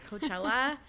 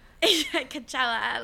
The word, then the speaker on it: Coachella: I don't know why I said it like that, Coachella. – You have to say it like that. – Coachella, like, we're like, okay, yeah, she could, like, do something wild then.